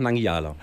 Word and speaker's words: Nangijala: Mangijala oder? – Einfach Nangijala. – Das Nangijala, ist tatsächlich ein Begriff aus einem Astrid Lindgren-Roman, aus dem Brüder Löwenherz.